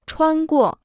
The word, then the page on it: 穿过: chuān guò